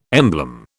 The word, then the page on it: emblem: British English